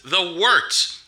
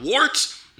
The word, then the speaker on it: Wort: It is The Wort, not wart, not fort, not short, The Wort.